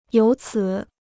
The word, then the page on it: 由此: yóu cǐ